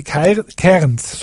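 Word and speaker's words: Cairns: Kerns, also C-A-I-R-N, also diese Dinge. Die Kerns, so spricht man das aus.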